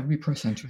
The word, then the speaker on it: reprocentrism: So, like, I know one that you've spoken about previously, is it called reprocentrism? – Oh, yes. – Am I saying it correctly? – Yeah, reprocentrism.